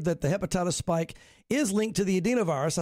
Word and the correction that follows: adenovirus: I said it wrong. The adenovirus, which is like the common cold.